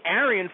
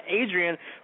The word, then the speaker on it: Arian: Wyatt, and everybody mispronounces uh, Foster. It's actually Arian Foster, not Adrian.